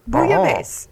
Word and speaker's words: bouillabaisse: And actually, we're close. Bouillabaisse. – Bouillabaisse. – That's how they say it. Bouillabaisse. Uh-huh. – We got that right.